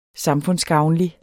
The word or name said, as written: samfundsgavnlig